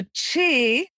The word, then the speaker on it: qi: So qi, spelled Q-I, pronounced C-H-I, basically the energy, our vital energy, how organs function with qi.